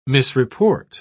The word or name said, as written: misreport